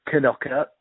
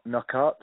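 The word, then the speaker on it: Knockaert: For the record, one of the first things he told me when he arrived at the Foxes was that it's pronounced Knockart, not Knockart.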